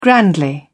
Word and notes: grandly: British English

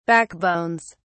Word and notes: backbones: British English

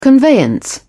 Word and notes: conveyance: British English

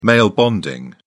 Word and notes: male bonding: British English

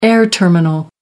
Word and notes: air terminal: American English

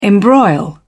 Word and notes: embroil: British English

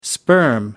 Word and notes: sperm: American English

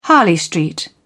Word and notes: harley street: British English

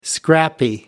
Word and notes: scrappy: American English

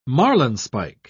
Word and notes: marlinespike: British English

